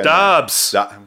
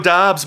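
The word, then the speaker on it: Doubs: And, you know, I start rethinking this entire, like, fuck, man, like I got to go for the Romeo Dubs of the world or the Puka Dobbs. – Okay, gotcha. – Romeo Dobbs, my friend. – I guess the U is silent.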